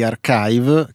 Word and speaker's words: arXiv: E proprio questa settimana quelli di Archive, che si scrive ARXIV, però poi si dice Archive ed è l'archivio più usato per la pubblicazione in anteprima di articoli di biologia, fisica, matematica, informatica, finanza e statistica.